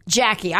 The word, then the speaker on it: Jaci: Do you pronounce her name Jackie or JC Velasquez? – I've heard Jackie. – Jackie. I have too, but it's spelled J-A-C-I.